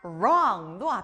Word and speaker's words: wrong: 틀리다, wrong도 앞에 R 소리가 나네요.